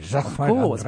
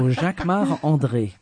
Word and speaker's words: Jacquemart-André: You say it like André. – A Frenchman. – Jacquemart-André. Because I'm French. So Jacquemart-André.